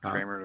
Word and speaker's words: Kremer: K-R-E-M-E-R. – Yeah, it sounds like Kramer to me.